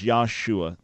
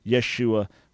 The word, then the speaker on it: Yeshua: By the way, Pastor, there's no such person as Yahshua. That's an incorrect name, pronunciation. That person never existed. Is Yeshua. Yeshua would be the way his name was pronounced.